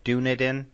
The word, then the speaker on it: Dunedin: Dunedin? – That's right.